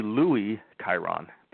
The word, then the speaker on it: Louis: Well, it's actually Louis Kyron, but I'll go by Lewis.